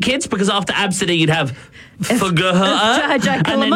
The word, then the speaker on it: Abcde: Absidy sounds all right, but it's spelt A-B-C-D-E. It's the first few letters of the alphabet, Absidy.